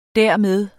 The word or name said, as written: dermed